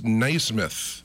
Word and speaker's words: Naismith: Did you know, during the 1936 Olympics, the medals for basketball were awarded by Dr. James Naismith. I guess that's how you pronounce his last name. – Yeah, it is.